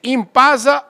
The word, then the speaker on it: Inpasa: É, Impasa Oil Premium. IOP. – É assim que pronuncia? É assim mesmo, perfeito.